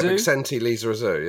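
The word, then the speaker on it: Bixente Lizarazu: I don't even know how you pronounce his surname. Like, is it Bicente Lizarazu? – Yeah, Bicente Lizarazu, yeah.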